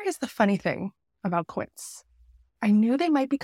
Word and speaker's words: Quince: Quince, it rhymes with Prince, which I love because I am a huge Prince fan.